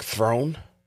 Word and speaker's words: Theron: We're mispronouncing her last name. – No, what's how do you pronounce her last name? She said it was like Throne.